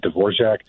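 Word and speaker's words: Dvořák: But between the two, the Dvorak and the Tchaikovsky, I think the Dvorak, I cannot say his name right, Dvorak. – Yeah, you said it perfectly.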